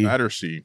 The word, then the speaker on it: Battersea: Battersea.